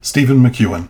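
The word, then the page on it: Steven McKoen: English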